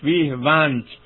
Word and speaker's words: ویه ونت: در اوستا نام این نقش‌ورز اسطوره‌ای ییمه هست و پسر ویه ونت در واقع تلفظش ویه ونت نام پدرشه.